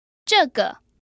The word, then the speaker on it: 这个: 这个 So that's a fourth tone in the neutral tone.